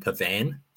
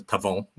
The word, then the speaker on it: Pavon: I'm glad that Spencer pronounced Matthew Pavon correctly, because the commentators at the Sony Open were driving me mad by saying calling him Pavan. They were calling him Pavan the whole time. There was an Italian golfer called Andrea Pavan, but it's Pavon, which Spence correctly pronounced.